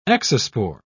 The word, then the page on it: exospore: British English